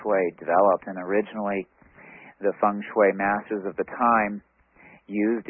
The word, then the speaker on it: feng shui: The pronunciation looks, it's spelled looking like feng shui or feng shui, but it's actually pronounced feng shui, like the E with a U.